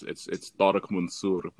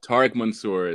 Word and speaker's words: Tariq Mansour: Yeah, I mean, it's Arabic, so it's Tariq Mansour, but... – Tariq Mansour. – Yeah, but Tariq, okay. Tariq Mansour is generally how I go by this.